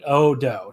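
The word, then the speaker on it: Ōdō: Odo. It's just, like, a long O and another long O at the end.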